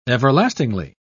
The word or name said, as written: everlastingly